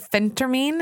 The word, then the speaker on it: phentermine: Is that the phentermine? Am I saying that right? – You did start it. – Yeah. I said it right.